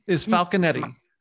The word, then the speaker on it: Falconetti: It's Fulconci, so I that's her last name. – Nope, it is Falconetti. Falconetti, I believe it's is how it's pronounced.